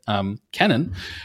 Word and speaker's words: Kennan: So I am listening right now to John Lewis Gaddis, the Yale historian's biography of George, is it Kennan or Kennan? I realize I actually don't know. I'm embarrassed to say I don't know how to pronounce it. It's Kennan. Okay, Kennan.